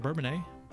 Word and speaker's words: Bourbonnais: Bourbonnais, is that pronounced that right? Bourbonnais. – Yeah, that's correct.